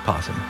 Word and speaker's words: opossum: For just pronounce possum. – It says opossum. – That's how you spell it, but it's just possum.